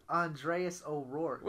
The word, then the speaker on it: Andreas O'Rourke: You said his whole name wrong. – What's his name? – Andreas O'Rourke. – What'd I say? – You said... – Andreas. – You said like Andrea Roki.